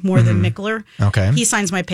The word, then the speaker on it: Mikkeller: Um, first of all, even to pronounce the name of your beer, it's M I K K E L L E R. Sure. – So Mikkeller.